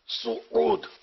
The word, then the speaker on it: سعود: یہ سعود لفظ تلفظ غلط ہے, سین پر پیش آتا ہے سعود, جیسے سعودی عرب ہے نا بولتے ہیں نا, تو یہ سین پر پیش ہے سعود.